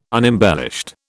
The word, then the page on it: unembellished: British English